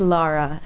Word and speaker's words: Lara: Yes, it's Laura, L-A-R-A.